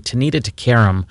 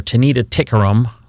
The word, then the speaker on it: Tanita Tikaram: Here's another great song that we used to play back in the day, Tanita Tikaram, or Tanita Tikaram. We pronounced it both ways, I think, in the day.